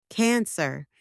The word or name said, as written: cancer